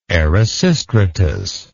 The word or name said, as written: erasistratus